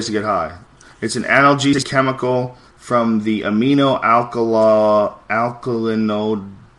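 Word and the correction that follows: aminoalkylindole: Yeah, amino alkylindol family, which acts as a cannabinoid, blah, blah, blah, blah, blah.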